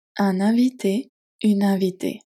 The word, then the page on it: un invité, une invitée: French